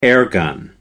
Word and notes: airgun: American English

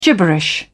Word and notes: gibberish: British English

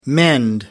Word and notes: mend: American English

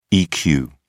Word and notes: eq: British English

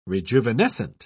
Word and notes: rejuvenescent: British English

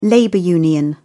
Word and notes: labor union: British English

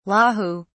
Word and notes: Lahu: British English

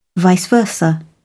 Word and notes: vice versa: British English

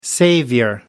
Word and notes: savior: British English

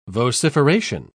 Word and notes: vociferation: British English

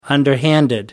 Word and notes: underhanded: British English

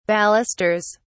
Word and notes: balusters: British English

